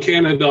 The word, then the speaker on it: Canida: Well, it's John Canada on Facebook, JOHN. CANIDA. It looks like Canada, but it's pronounced like the country.